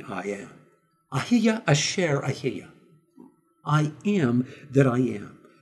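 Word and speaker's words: Ahiya Asher Ahiya (אהיה אשר אהיה): In Hebrew, you would pronounce it like this, Ahiya Asher Ahiya, meaning I am that I am.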